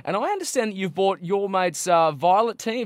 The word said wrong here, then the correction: Violetine: Have Teen, if I said that right? – Violetine.